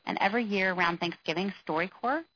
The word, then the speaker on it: StoryCorps: So the program StoryCorps, and I'll spell that, StoryCorps, so core like Marine Corps,